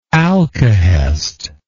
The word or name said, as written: alcahest